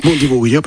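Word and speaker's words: Bundibugyo: ¿qué diferencia esta cepa Bundi Vuyo, que no sé si lo estoy pronunciando bien. – Bundi Vuyo, perfecto.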